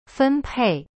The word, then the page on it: 分配: fen pei